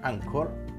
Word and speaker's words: Anchor: Estamos en Twitter y en Instagram como Igloo Radio y nos puedes escuchar en Anchor, Anchor, en realidad se pronuncia, y en Spotify.